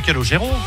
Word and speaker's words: Calogero: Tout le monde veut le prononcer à l'américaine Calogero. – Calogero. – C'est Calogero? – Oui.